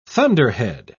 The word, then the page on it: thunderhead: British English